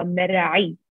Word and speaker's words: Merhi: I am actually based in Dubai, and the way that I say my name is Sara Merhi. – That's so cool. Yeah. – It's a bit heavy. Merhi, but that's how I say my name.